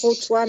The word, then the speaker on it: Khoutwa: Ça s'écrit K-H-O-U-T-W-A. Ça se prononce Routois, mais ça se dit K-H.